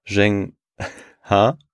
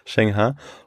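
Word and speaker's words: Zheng He: Und ich fand, das war eine ganz interessante Geschichte zu dieser Person, dem Seefahrer und dem Eunuchen Zheng Ha. – Ja. – Hast du richtig ausgesprochen? – Ich glaube schon. – Okay. – Ja, das klang gut. – Zheng Ha.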